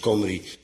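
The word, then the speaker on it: Cymry: Maar ook in onze dagen zijn de Welsh, dat wil zeggen de inwoners van Wales, los de enigen nog die als Kimri, uitgesproken als Comri, worden aangeduid.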